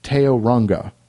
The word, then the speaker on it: Tauranga: and heard a guy from Tauranga, and he tells me how to pronounce it. He says it's pronounced Teoranga.